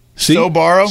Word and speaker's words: Sbarro: Not a P sound. It's a B sound. Yeah. See. – Sbarro. – See. – S-O-B-A-R-R.